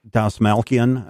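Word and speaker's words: Dastmalchian: David Desmalchain. Is that how you say his name? – Dasmalkian.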